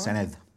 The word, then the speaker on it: Senedd: In terms of the Assembly, I've been to First Minister's questions at the Senedd. Have I pronounced that right? – Senedd.